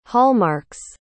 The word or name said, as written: hallmark's